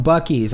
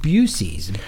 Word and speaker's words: Buc-ee's: From Buc-ee's. – I've heard folks pronounce it Busey's.